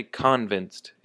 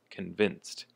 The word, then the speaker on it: convinced: So here the emphasis is on the vin, not on the con. Don't say convinced, it's convinced.